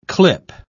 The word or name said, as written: clip